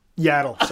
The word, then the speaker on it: Yaddle: Waddle? – Waddle. – Yeah. – It's not Yaddle? – Yaddle, sorry.